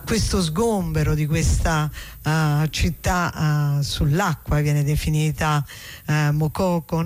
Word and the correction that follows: Makoko: Mokoko, con l'accento? – Makoko, sì, non so se è con l'accento finale alla francese, Makoko, però probabilmente siccome quello è un paese anglofono sarà in maniera diversa.